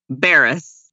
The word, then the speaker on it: Cabarrus: And so I love y'all, but I'm going to tell you how to pronounce it. – Oh, please. No. Say it again. Cabarrus? – Barris.